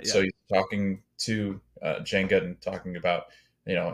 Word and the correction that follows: Jango: So you butchered two of those names. – What? Which one? – Dooku. – Oh, sorry. – And Jango.